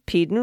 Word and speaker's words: Peden: Peden rhymes with Eden.